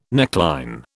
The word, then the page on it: neckline: British English